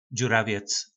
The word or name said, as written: dzuravec